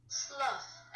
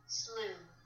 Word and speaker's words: slough: Slough, as in a snake skin. Otherwise, slough. Also American, slew.